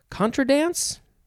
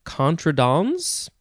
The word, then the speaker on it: contra dance: Next is contra dance. Two words. Contra dance? Contra dance. You could also say contradans. And you can spell it contradance.